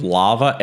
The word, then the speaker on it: LLaVA: And then so the second one was, you pronounce it sort of LAVA, L-L-A-V-A, Large Language and Vision Assistant.